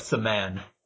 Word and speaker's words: semaine: Saman. – The topic. What now? – Well, my pronunciation may be a little rough, but it's like semaine.